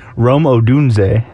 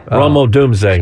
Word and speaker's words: Rome Odunze: How do you say it? – Rome Odunze. – Rome Odunze. – Excuse me. I thought it was Odunze. Odunze. – Okay. Rome Odunze.